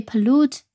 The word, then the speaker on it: pollute: 那么这个最后的一个 e 是常常用的一个不发音的一个 e，所以 pollute 它的拼写就是 p o l l u t e。